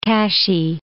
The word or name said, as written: Kashi